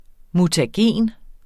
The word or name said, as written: mutagen